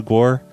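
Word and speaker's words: GWAR: I just wanted to say, hey, you should listen to Guar. They're probably one of the best bands to ever exist, period. – I know of them. – So, please listen to Guar. – It's pronounced G-War.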